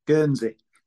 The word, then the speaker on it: Guernsey: Or maybe you were talking about whether to move back to Gurney. Did I say it right? – Guernsey.